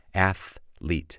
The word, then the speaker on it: athlete: Remember how you pronounce the word athlete. It has two syllables, athlete.